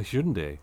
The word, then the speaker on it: Hyundai: He'll be back in a Hyundai. – A Hyundai. – Yes, that's the way I always pronounce it. I still pronounce it now, but it's Hyundai.